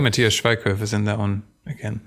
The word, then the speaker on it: Schweighöfer: Matthias Schweighöfer. Matthias is the Matthew of Germany. – Schweig. – And then S-C-H.